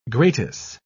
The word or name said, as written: gratis